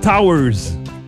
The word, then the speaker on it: TTWWRRSS: Et du côté anglophone, on retrouve un groupe qui s'écrit TTWWRRSS. – Qu'est-ce que c'est ça? – C'est Towers.